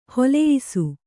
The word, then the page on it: ಹೊಲೆಯಿಸು: holeyisu